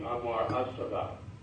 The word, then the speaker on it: Anwar As-Sadat: I know in a scholarly manner, the L is normally rendered as Al, A-L, but the L is not even pronounced in the correct Arabic pronunciation. I understand this would be Anwar As-Sadat.